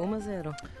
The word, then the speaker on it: Uma Zero: And there is a song on here, Uma Zero. Am I pronouncing that right? Uma Zero, 1-0? – Yeah, 1-0, Uma Zero.